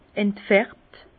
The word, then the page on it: entfärbt: Bas-Rhin; Herrlisheim